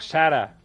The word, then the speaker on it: Zara: Zara se dice así. Zara... O Sara, por ahí fuera.